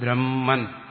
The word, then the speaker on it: ബ്രഹ്മ: ബ്രഹ്മ ബ്രഹ്മ അതിൽ ബ്രഹ്മം, നമ്മൾ സാധാരണ ബ്രഹ്മ എന്ന് പറയുമ്പോൾ അതിൻ്റെ അകത്ത് ഹായുണ്ട്. മറ്റ് രണ്ട് ശബ്ദങ്ങളുടെ ഉച്ചാരണത്തിൻ്റെ പ്രാധാന്യം കൊണ്ട് ഹകാരം വ്യക്തമായി ശ്രവിക്കുന്നില്ലെന്നേ ഉള്ളൂ.